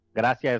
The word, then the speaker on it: Gracia: Gracia is gracias, but without the S. Gracia.